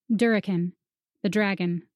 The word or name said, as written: Durriken